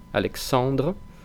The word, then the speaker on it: Alexandre: En cuanto a los nombres propios que aparecen ahí y a su pronunciación, Alexandre no tiene nada extraño que no hayamos visto hasta ahora, la AN nasal, en, Alexandre.